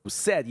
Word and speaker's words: Musetti: Musetti, you said it right. Correct.